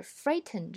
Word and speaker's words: frighten: 美式英语呢里呢，由于 t 这个音标的变化，导致 frighten 这个词并不这样发。在美式英语里呢，这个单词结尾是 t 加 n 这样的发音的时候呢，会 hold 住 t 的发音。